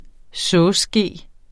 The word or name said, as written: sovseske